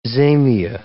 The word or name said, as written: zamia